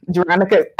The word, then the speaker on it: Jeronicus: and and um, the main character, um, Ger- Geronimus. I don't want to pronounce mispronounce his name, Jeronicus.